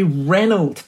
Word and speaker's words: Renault: So Tobin Ober mentions Mary Renault, who we haven't mentioned at all. – It's Mary Renault. – Yeah. Renault. Shocking. – It really is Mary Renault. – The people to affect a French pronunciation, I would be the last that anyone would expect.